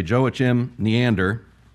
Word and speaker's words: Joachim Neander: The hymn is "Praise to the Lord, the Almighty," by a man named, not sure how he would pronounce it in German, but since I'm not German, I'm going to say Joachim Neander, who lived from 1650 to 1680, a mere 30 years.